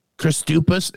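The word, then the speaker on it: Kristupas: K-E-I-N-Y-S. – Okay. – Christupas. It's K-R-I-S-T-U-P-A-S is the first name. Christupas.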